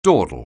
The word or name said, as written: dawdle